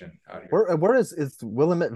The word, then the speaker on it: Willamette: Yeah, so um, I, I learned this when I moved to Portland, but uh, I got corrected several times, but it's the Willamette, damn it.